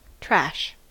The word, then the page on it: trash: American English